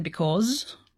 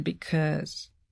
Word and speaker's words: because: Мы можем сказать because, можем сказать because, можем сказать because. Это шва, и он ударный.